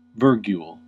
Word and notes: virgule: American English

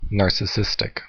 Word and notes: narcissistic: American English

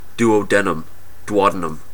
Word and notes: duodenum: American English

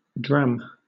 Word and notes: dram: British English

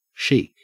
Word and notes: chic: Australian English

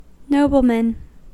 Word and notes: nobleman: American English

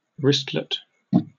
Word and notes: wristlet: British English

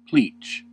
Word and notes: pleach: American English

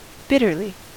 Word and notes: bitterly: American English

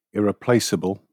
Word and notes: irreplaceable: British English

